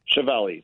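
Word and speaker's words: Schiavelli: Yeah, Chiavelli.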